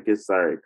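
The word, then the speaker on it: Githzerai: Am I saying that right correctly? It's the Gisari, correct? Gisari, there we go.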